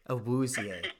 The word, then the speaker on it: Awuzie: Say it with me. Chidobe. – Chidobe. – Chidobe. – Chidobe. – Awuzie. – Awuzie.